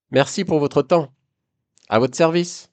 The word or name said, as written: a votre service